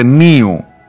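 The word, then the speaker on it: nu: Όμως οπουδήποτε εκτός Ελλάδας και Κύπρου η προφορά τους δεν είναι όπως ίσως θα νόμιζε κάποιος εδώ. Έχουμε, έχουμε λοιπόν μου προφέρεται μίου και νου προφέρεται νίου ε,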